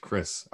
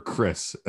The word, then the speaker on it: Krzys: But it's a lot easier to say than it looks. It's pronounced Chris. – Oh my gosh, Chris. Okay. So Carolyn Freyer Jones and Amber Chris.